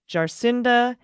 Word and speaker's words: Jacinda: By the way, the pronunciation of our prime minister's name is Jacinda Ardern. Emphasis on the sin and the dern.